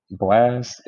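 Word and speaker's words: Blxst: Blast. It's spelled like B L X S.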